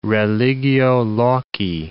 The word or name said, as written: religio loci